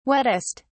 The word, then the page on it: wettest: British English